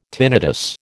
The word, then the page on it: tinnitus: American English